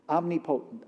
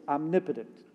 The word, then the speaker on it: omnipotent: I heard this guy on the internet kept saying, omnipotent, omnipotent. That is not how you pronounce it. It's omnipotent, but he's all-powerful.